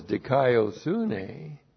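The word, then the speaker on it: dikaiosune (δικαιοσύνη): D-I-K-A-I-O-S. Dikaios means righteous. Righteous. And then you have the noun. The noun is dikaiosune. Looks like that in the Greek. D-I-K-A-I-O-S-U-N. Long E, that's the noun.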